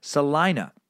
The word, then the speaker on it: Salina: The town of Salina is pronounced with a long I sound, not a long E sound. Salina. And K-A-K-E News is simply pronounced as cake.